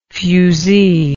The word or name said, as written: fuzee